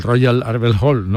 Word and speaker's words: Royal Albert Hall: No, yo creo que lo has dicho perfectamente. – Arbel, ¿no? Albert. Arbel, pues ya está, lo he dicho en andaluz.